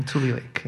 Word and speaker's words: Tule Lake: Did I pronounce that correctly? – Mm-hmm. Tule Lake. Yeah.